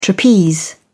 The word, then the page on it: trapeze: British English